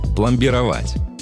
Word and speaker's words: пломбировать: Одно из них – пломбировать. Да, ударение в нем ставится именно так и никак иначе.